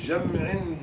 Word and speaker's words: جمع: جمع لمزدلفة اسمها مزدلفة، المشعر الحرام وجمع بتسكين الميم. أي نعم. وجمع المرأة التي يعني ماتت امرأة في جمع بجمع، ماتت امرأة في جمع أي بمزدلفة بجمع أي وهي حاج، فالكلمتان الرسم واحد.